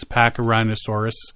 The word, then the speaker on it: Pachyrhinosaurus: The Pipestone Creek bone bed is one of the richest deposits of Pachyrhinosaurus. It's got to be rhinosaurus, not rhinoceros, because it's spelled different. – Yeah, I think it's rhinosaurus. – Pachyrhinosaurus.